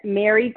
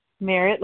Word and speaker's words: Merritt: Is that Mary W? – Merritt, like the badge.